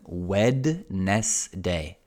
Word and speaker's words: Wednesday: Tuesday can be difficult, martedì, Tuesday, Wednesday, è veramente strano, because Wednesday is written Wednesday, but no one says Wednesday, we say Wednesday, as if the first D weren't there, Wednesday.